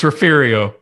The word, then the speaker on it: Refugio: I never say the name right. You'll hear it 20 different ways. Refugio, Texas, down there in South Texas. They've got themselves. – It's Refugio. – Exactly.